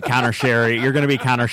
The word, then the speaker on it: Sheary: And also, apparently we've been pronouncing Connor Sheary's name wrong. – Have we? – Yeah. He came out today. Apparently, after 3 years, he finally just said, guys, you're pronouncing my name wrong. It's pronounced Sherry.